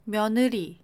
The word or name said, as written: 며느리